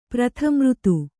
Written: ಪ್ರಥಮಋತು

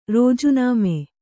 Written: ರಾಜೀನಾಮೆ

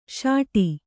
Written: ಸಟ್ಟಾ

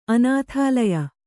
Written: ಅನಾಥಾಲಯ

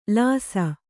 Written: ಲೇಸು

